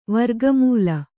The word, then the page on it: ವರ್ಗಮೂಲ: kannada